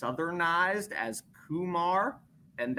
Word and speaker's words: Kumar: And I don't think he's cares too much which way it goes, but I say Kumar. That's how he told me it was said the first time I met him when I specifically asked, and that is the correct Indian origin ethnic pronunciation.